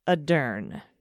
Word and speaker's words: Ardern: By the way, the pronunciation of our prime minister's name is Jacinda Ardern. Emphasis on the sin and the dern.